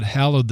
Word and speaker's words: hallowed: Sorry, that's my mispronunciation. You might get misunderstood. He didn't hollow out the Sabbath, but thus God hallowed the Sabbath,